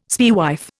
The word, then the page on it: spaewife: Indian